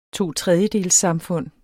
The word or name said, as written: totredjedelssamfund